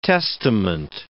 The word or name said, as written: testament